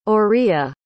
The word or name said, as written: orilla